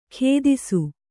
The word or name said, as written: ಖೇದಿಸು